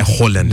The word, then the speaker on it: Haaland: Mas tu viu que o nome dele não se fala Haaland? – É Haaland.